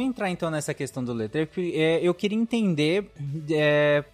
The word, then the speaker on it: Letreco: a pronúncia correta, por favor. Let... A terceira vez você. Treco. – Você Põe um acento. Letreco. Tá Isso, bom.